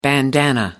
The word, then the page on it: bandanna: British English